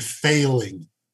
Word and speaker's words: Fahling: Guy named Failing. There's a little irony for you there. Yes, it's spelled F-A-H-L-I-N-G, but I know what I heard on that broadcast.